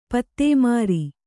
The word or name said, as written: ಪದಿಮೂರು